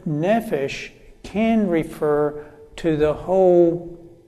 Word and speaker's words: nephesh: The Hebrew word that is translated being in the New International Version and many other versions is the word nephesh. Nephesh, and I'll spell that for you, N-E-P-H-E-S-H, N-E-P-H-E-S-H, nephesh.